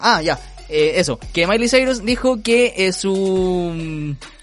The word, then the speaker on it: Miley Cyrus: Porque eh, Miley Cyrus, ¿lo dije bien? – Miley Cyrus,